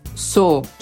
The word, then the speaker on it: sore: Jest jeszcze jedno słowo, które po brytyjsku wymawia się jako su, czyli kolejny homofon do widziałem, widziałam, a mianowicie słowo zapisywane jako sore.